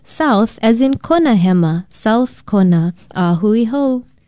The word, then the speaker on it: hema: Aloha, I'm Lilani Poliahu of Hawaii Public Radio, and our Hawaiian word for today is hema. Hema is spelled H-E-M-A, and it means left or left side.